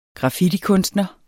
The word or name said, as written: graffitikunstner